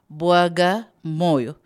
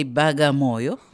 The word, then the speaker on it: Bagamoyo: Bwaga Moyo, but these white people couldn't pronounce Bwaga Moyo, so they say Bagamoyo.